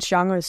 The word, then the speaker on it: genres: So, and you can tell stories in so many different ways and in so many different genres. So it's not genres, is it? How do you say that? – Genres. Yeah, perfect.